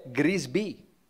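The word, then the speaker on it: Grisbì: L'unica parola positiva che mi è venuta in mente è Grisby. Però non vale perché lì c'è l'accento che alla fine che rende tutto più felice e gioioso, no?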